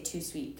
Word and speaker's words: Tout Suite: It's called Tout Suite. – Tout Suite. – I don't think you say the T though. I think you say Tout Suite because no, it's like French.